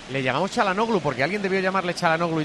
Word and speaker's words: Çalhanoğlu: Pero yo le pregunté a un amigo de Arda Turán y me dijo que era Çalhanoğlu. – Çalhanoğlu. Sí, pero. Es que la H se pronuncia.